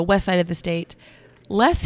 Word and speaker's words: Loess: I pronounced them both less, but. – Oh, okay. – Yeah. – So L-O-E-S-S Hills are the ones that are the geographic, whatever you want to call them.